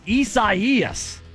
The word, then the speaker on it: Isaias: Come on, Isaias. That's how you say it.